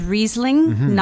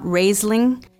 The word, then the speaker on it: Riesling: So the pronunciation is Riesling, not Raisling.